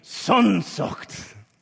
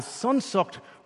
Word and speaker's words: Sehnsucht: There's a German word that does not translate well to English, but the German word is Sehnsucht. And you got to say it like that because it's part of the meaning. Sehnsucht. And the best English translation we can have is Sehnsucht refers to a deep yearning, a longing for, a desire.